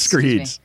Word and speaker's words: Schreids: You can find me on Twitter at Shrides, S-C-H-R-E-I-D-S. Matt will tell you it's pronounced Shreeds. – Nope, Screeds. – It's Screeds.